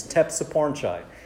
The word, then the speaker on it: Tepsipornchai: It's Tepsipornchai. And uh, I actually studied computer science and engineering at UCLA, and so the joke after that was that it rhymes with tech support guy.